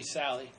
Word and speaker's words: Sale: It's actually Sally. – It's Sally. Oh, spelled the same way.